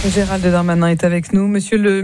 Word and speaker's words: Gérald Darmanin: Pardon, vous n'avez pas le prononcé mon nom. Mon nom, c'est Gérald Darmanin. – Darmanin. Oui. – Darmanin, D-A-B-A-D-I-N.